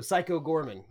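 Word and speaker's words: Psycho Goreman: Well, it's spelled Psycho Gorman, but Gorman is like one word, Gorman. So Psycho Gorman.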